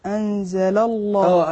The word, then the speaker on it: الله: أنا قلنا اللامين ورا بعض طبعا ألف الوصل أو همزة الوصل الله اللي هي الألف ديت بتسقط في وسط الكلام فصار عندنا هننطق اللامين ورا بعض هقول أنزل الله أنزل لا الله ما هوش لا الله لا أقول لا الله يبقى أنا رققت اللام الأولانية على الأصل إن اللام مرققة في الأصل